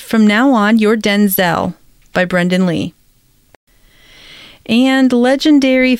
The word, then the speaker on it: Denzel: During a September 2nd appearance on Jimmy Kimmel Live, the actor, who's 70, told the host that his name is Denzel, with a clipped E sound rather than Denzel.